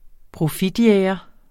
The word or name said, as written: profitjæger